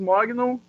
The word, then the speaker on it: Mognon: Eu sou o Matheus Mognon,